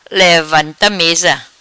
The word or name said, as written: Levanta Mesa